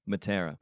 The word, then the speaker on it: Matera: Matera. – Is the M silent? Is it just Atera? – Nope. It's okay. Still there.